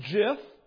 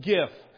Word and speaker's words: GIF: There are those who pronounce it jif, and there are those that are correct and pronounce it gif.